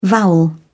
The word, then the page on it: vowel: British English